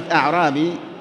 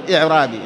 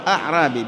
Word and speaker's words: أعرابي: الأعرابي، كلمة أعرابي هكذا، أعرابي، بعضهم ينطقها خطأ يقول إعرابي، لا، هو أعرابي بفتح الهمزة أوله نسبة إلى الأعراب، والأعراب هم سكان البادية سواء كانوا عرباً أو عجماً.